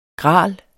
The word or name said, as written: gral